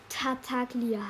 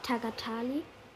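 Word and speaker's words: Tartaglia: Tataglia heißt er. Nicht Tagatali, sondern Tataglia. Ich habe das immer komplett falsch ausgesprochen. Sorry. Aber jetzt weiß ich, dass Tataglia heißt. Und nicht Tagatalia. Bla bla bla bla bla bla bla bla bla bla bla bla bla bla bla bla bla bla bla bla bla bla bla.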